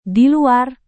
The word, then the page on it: diluar: indonesian